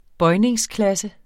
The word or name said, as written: bøjningsklasse